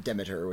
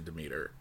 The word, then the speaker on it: Demeter: I always pronounce it Demeter, which I think is the American pronunciation. – Yeah, but it's, I believe the correct one is actually Demeter. – If you're British or Greek.